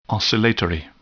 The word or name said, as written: oscillatory